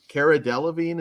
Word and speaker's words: Cara Delevingne: And speaking of resting bitch face, Cara Delevingne, is that how you say her name? – Yeah.